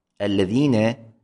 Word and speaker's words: الذين: المثال الثاني، الذين، الذين، الذين تلفظ بلامين، ال ل ال ل، هناك لام ساكنة ولام مفتوحة، تلفظ بلامين لكنها تكتب بلام واحدة، لام مشددة طبعا، لكن بالنتيجة هي لامان، اللام المشددة هي لامان وليست لام واحدة كما لعله يتوهم، هناك لامان.